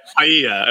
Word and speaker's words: paella: You're talking about Palela. – Yeah. Palela. – Paella, I believe is how it's pronounced.